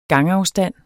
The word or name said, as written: gangafstand